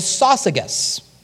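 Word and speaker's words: Syzygus: So, companion is a name, and the name is Sosigas. I remember it because it's like sausages with a hard G.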